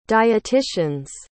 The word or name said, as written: dietitians